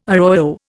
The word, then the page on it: arroyo: Indian